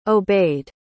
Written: obeyed

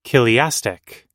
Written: chiliastic

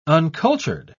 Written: uncultured

